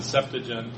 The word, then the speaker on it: Septuagint: We talked last week about the Septuagint, or you could pronounce it Septuagint, or various pronunciations.